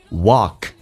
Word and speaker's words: walk: 英式发音叫 walk，美式发音如果它是长元音，没有字母二组合在它的发音当中。他打开口腔一点点，稍微夸张一点点，有点像澳大利亚的澳那个中文字叫 walk， just walk forward，两次卷舌，在美式发音叫 for，嘴巴收圆， word 扁一点点， forward 往前，再前进一点，再往前迈步。